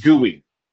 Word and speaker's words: GUI: I mean, I can understand it if you're looking at it as like a regular English word, but it's actually pronounced GUI. You know, like GUI, like it's goo, like if you were holding goo, you'd say it was GUI.